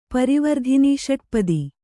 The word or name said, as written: ಪರಿವರ್ಧಿನಿಷಟ್ಪದಿ